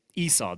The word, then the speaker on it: Esau: So it's like this kind of, oh, yeah, wow, that's a hairy baby. What, did you say his name was Hairy? Oh, no, I thought you said Hairy. It's actually Esau, the Hebrew word. So she names him almost immediately.